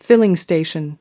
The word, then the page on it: filling station: American English